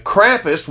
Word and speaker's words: Krampus: Who is Krampus or Krampus? However you want. It's K-R-A-M-P-U-S. I pronounce it Krampus.